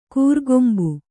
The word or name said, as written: ಕೊರೆಕಂಬಿ